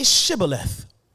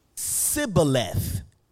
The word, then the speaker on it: shibboleth: And when he said, No, they said to him, Well, say Shibboleth. and then they would say, Sibboleth, for they could not pronounce it, pronounce it right.